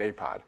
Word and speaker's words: APOD: Astronomy Picture of the Day is abbreviated APOD and pronounced APOD. It, there should be a T in there, but it sounds strange if you try to pronounce the T in Astronomy Picture of the Day.